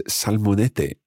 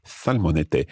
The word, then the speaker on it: salmonete: But in the recording, you'll hear the fishmonger pronouncing it as salmonete, and that's where the th sound, the th sound, if you like, is replacing S, as it happens in some parts of Spain.